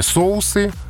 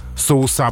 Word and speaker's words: соусы: Ну и в случае со словом «соус», разумеется, процесс тот же самый. Здесь эталонное ударение «соусы». «Соуса» пока расценивается как разговорный вариант.